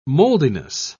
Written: moldiness

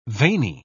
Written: veiny

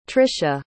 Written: tricia

